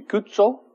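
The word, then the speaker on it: Cuozzo: How do you say pizza? Don't you put a T in front of the Z's, an Italian word? So it'd be Cuzzo.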